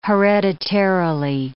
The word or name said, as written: hereditarily